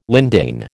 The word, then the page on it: lindane: American English